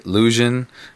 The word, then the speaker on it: Llusion: This is your boy, Lusion. That is spelled L-L-U-S-I-O-N.